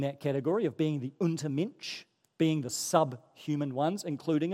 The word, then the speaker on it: Untermensch: Untermensch is how it's said in German. Untermensch.